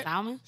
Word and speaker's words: salmon: Salmon? – Am I saying it wrong? – Hey, yo. – It's salmon. – Salmon? – L Silent, my boy.